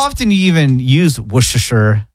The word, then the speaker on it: Worcestershire: So you don't say the R? You don't say Worcestershire? – No.